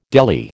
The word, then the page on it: deli: American English